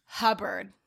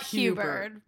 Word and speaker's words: Hubbard: It is Hubbard. – Yeah, not Hubert.